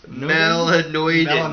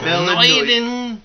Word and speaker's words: melanoidin: Melanonin? – Melanoidin. – Melanoidin.